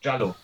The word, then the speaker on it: Giallo: Ähm, und natürlich äh, ist ähm, Mario Bava irgendwie bei diesem ganzen äh, italienischen, wie spricht man es aus, Jello, Giallo? Giallo.